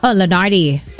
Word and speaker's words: Lenardi: So this is with that popular content creator as a chef, Leonard. – Lenardi. – Oh, is that how you say his name? – Yeah. – Very nice guy. I've met him a couple of times, but I can never get his name right. I just don't dare to ask him. – I mean, he's like Leonard Yong. – Yeah. Is it Lenardi? – Yeah, Lenardi.